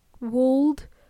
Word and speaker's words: would: By the way, when we pronounce the words would and could, the L is silent. I don't want to hear you say would or could, okay? Would and could. Okay, it's a long oo.